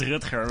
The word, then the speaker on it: Rutger: It's Rutger, but it's very, very hard to pronounce, I know.